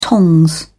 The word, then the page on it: tongs: British English